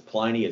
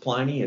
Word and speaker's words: Pliny: The other interesting things folks might care about is Pliny is actually not pronounced Pliny. It's pronounced Pliny.